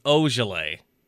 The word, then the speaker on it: Olajuwon: People are already turning on Shemi Olajuwon. – Olajuwon. – Damn it. What the? Wait, that's what I always say. – You always say Olajuwon. It's Olajuwon.